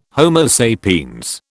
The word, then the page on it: Homo sapiens: British English